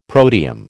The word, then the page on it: protium: American English